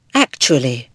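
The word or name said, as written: actually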